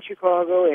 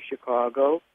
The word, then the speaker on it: Chicago: Neither one of you, although you live in Chicago and you work in Chicago and your show is broadcast from Chicago, neither one of you pronounced this city's name correctly, especially you, Jim. You really mispronounce it. It's Chicago, not Chicago.